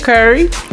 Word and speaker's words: Curry: It's Shakira Curry. First name, I just spelled the last name. C-U-R-R-Y, like the spice.